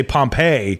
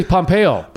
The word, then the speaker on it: Pompeo: I always want to say Pompeii, right? – That's what I'm thinking of, or Papaya. – I say Pompeo.